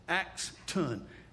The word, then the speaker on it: Axton: This is Axel. Talk. Did I say it wrong again? Axton. A-X-T-O-N. Is that right? I got it. Axton.